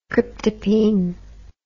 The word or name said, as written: cryptopine